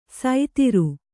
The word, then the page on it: ಸೈತಿರು: Kannada